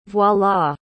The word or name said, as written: voilà